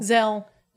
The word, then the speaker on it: Zelle: Zelle.